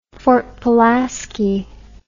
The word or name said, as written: Fort Pulaski